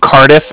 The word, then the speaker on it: Cardiff: And then the Bank of Cardiff is spelled C-A-R-D-I-F-F, as in Frank. So it's like car and then different.